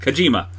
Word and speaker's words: Kojima: Kojima? – Jojima? – No. – Oh, the Kojima? – Kojima.